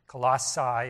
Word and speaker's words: Colossae: Colossae is how it's pronounced.